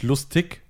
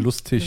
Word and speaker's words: lustig: Dann, was ganz wichtig ist, einer der wichtigsten Regeln ist, alles, was auf IG endet, also das meiste, also eigentlich fast alles, wird mit ich ausgesprochen. – Also lustig. Hä? Nicht lustig, sondern lustig.